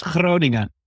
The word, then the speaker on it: Groningen: Yeah, it's pronounced Groningen. Yeah. With a, with, a, very hard G, which is almost impossible to learn uh, when you're a native English speaker.